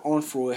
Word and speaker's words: Onfroy: But so his birth name, if you want to know more really details about him, his birth name is Josiah Dwayne Onfray, or Onfroy, however you pronounce it. I think it's Onfray, but it's spelled O-N-F-R-O-Y, but I think it's Onfray.